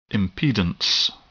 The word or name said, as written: impedance